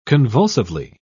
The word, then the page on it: convulsively: British English